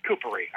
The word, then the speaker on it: Kupari: Um, so uh, once again, Mike, just uh, Kupari, right, is the way we should say your last name, and it's uh, Finnish.